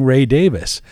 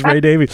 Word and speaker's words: Ray Davies: So throughout the interview, I'm saying Ray Davis. And then I get an avalanche of mail from people going, you interviewed this guy, you don't know how to say his name, you know, because people always thought it was Ray Davies.